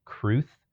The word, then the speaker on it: crwth: A lot of people are playing Scrabble and they've been intentionally posting words like Cruth is the one they did for St. Patty's Day, which is spelled C-R-W-T-H.